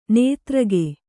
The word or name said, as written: ನಿದ್ರೆಗೈ